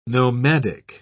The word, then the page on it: nomadic: British English